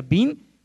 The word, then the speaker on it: bin: Eh, igual que Álvarez es filio de Álvaro, la eh, palabra bin se pronuncia bin, significa filio de.